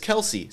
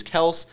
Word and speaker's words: Kelce: And he goes, my grandfather says it's Kels. And that's, I guess, how the real pronunciation is. And then his father, as it's gotten passed down, said that it's Kelce. So he says that it's Kelce, but the correct pronunciation is Kels.